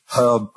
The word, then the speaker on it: herb: He was telling me really intensely that the English do not pronounce the H in herbalist, herb, all that stuff.